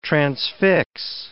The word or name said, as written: transfix